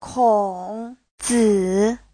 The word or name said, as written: kong3zi3